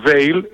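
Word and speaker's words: Weil: Όταν σας λέω ότι ειδικά τις πρώτες μέρες, αν δεν το σκεφτείς, βέβαια στην άλλη περίπτωση που ανέφερα την νόσο του Veyle ή Vile όπως θέλετε την προφέρετε,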